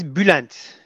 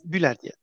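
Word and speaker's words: Bülent: Well, it's I'm Turkish. There is a letter U with the 2 points on the dot on the top. So it is U in Turkey. So it's Bulent. Pronounced Bulent.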